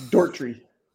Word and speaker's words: Doherty: Would you guys pronounce this Doherty or Doherty? – It's Dortry.